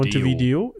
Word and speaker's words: Montevideo: Ja, 1844 ist er in die Loge Les Amis de la Patrie in Montevideo Video. – Montevideo, genau. Mir kam es auch gerade, dass ich es falsch ausgesprochen habe.